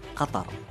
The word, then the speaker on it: Qatar: The official pronunciation of my country is Qatar.